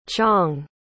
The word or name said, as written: chong